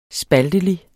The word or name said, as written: spaltelig